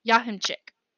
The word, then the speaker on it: Jachimczyk: Yeah, it's pronounced Yahinchik. – Yahinchik. – Mm-hmm. It's spelled right. with the J, but not. So we're going to call him Dr. Joseph. – Now this is J-A-H-E-N-C-E-K? – No. – Jahinchik? – J-A-C-H-I-M-C-Z-Y-K. – Of course.